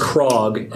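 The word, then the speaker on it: Krogh: I think I've most frequently seen it as Krog, as rhyming with frog.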